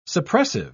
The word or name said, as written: suppressive